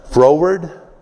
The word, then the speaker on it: froward: A froward boss, you know. Get in your face, boss. Okay? What's that? Froward. F-R-O-W-A-R-D. Froward.